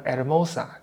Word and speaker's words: Hermosa: 然后像那个台湾不有个别名叫殖民地，殖民者给他起的名字叫福尔摩沙嘛，Formosa，但西班牙语就变成 Hermosa，就变成 H 了，那 H 也不发音。